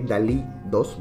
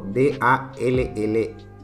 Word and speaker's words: DALL-E: El nombre de DALL-E 2, bueno, que 2 es la versión, proviene del artista español Salvador Dalí, pero se escribe como D-A-L-L-E, que es parecido al robot de Pixar, WALL-E, película que nos mostraba un robot con sentimientos.